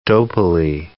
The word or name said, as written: dopily